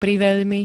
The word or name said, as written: priveľmi